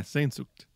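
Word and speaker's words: Sehnsucht: I listened to the episode on Sen Matt, how's that pronounced? Sensucht? – Oh yeah, Sensucht.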